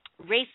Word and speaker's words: Ralph: If you like Ralph Fiennes, see, I know how to pronounce his name, which is R-A-L-P-H, but it's Ralph.